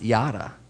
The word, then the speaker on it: yada: Well, that's how it's pronounced. I mean, it's actually in Hebrew, and it's like hieroglyphics. Which I can't read Hebrew, unfortunately, but apparently it's pronounced yada, which I thought was funny because what's the saying?